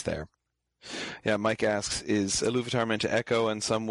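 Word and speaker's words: Ilúvatar: One question that people will sometimes have is where does the stress fall in Iluvatar? And it tends to be the second syllable there, Iluvatar.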